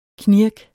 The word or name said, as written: knirk